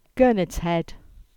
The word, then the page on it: Gurnard's Head: English